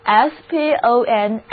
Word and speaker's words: sponsor: 最后一个包含 SPONS 这个词根的单词是 SPONSOR， sponsor，第一个字母 O 读作 O，后面的字母组合 OR 要读作 A。